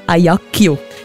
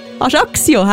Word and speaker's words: Ajaccio: Aber der Ort, wo sie herkommt, habe ich auch immer falsch gesagt. Ich habe gesagt, Ajaccio. Ajaccio heisst das, ist die Hauptstadt von Korsika.